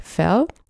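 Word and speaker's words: Verl: Ich habe es wohl in der vergangenen Podcast-Folge falsch ausgesprochen. Es heißt tatsächlich Ferl.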